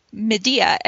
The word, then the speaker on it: Medea: Then this week, Kurt said she once stopped a performance of Medea, Medea, the, the play he was referring to is Medea, pronounced Medea, and it, I'm saying it right, right? – Yes, you are.